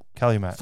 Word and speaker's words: Calumet: We have a Calumet farm. – Cal farm. – Yeah, Cal farm. – Calumet.